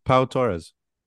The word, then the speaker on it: Pau Torres: Pau Torres.